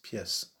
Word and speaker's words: pièce: In French, the word pièce, which looks like the word piece in English, piece as in a piece of cake, P-I-E-C-E, but it's spelt with an accent in French, and the pronunciation is pièce.